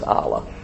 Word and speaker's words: Allah: That's what it, how you pronounce it is Allah.